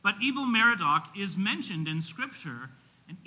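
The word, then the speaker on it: Evil-Merodach: After Nebuchadnezzar died, he was first replaced by one of his sons named, it's pronounced Evil Merodach. His name was not Merodach and he was really evil, okay? If we were to really say it, it would be Evil or Ewil.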